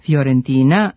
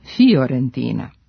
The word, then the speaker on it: Fiorentina: Ovo pravilo važi i za predvokalsko fi koje se čita i piše kao fj, Fiorentina, a ne Fiorentina.